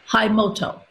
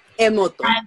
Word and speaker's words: Emoto: Masaru Hayamoto. – Emoto.